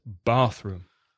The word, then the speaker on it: bathroom: Remember to put the stress on the first word, bathroom.